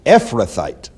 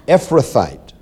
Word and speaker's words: Ephrathite: Ephrathite. The accent is on the F, right? Ephrathite of Bethlehem, Judah, whose name was Jesse, and he had 8 sons.